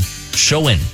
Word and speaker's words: Samhain: called Samhain. It's not spelled that way, but that's the way you pronounce it.